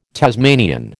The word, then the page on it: Tasmanian: American English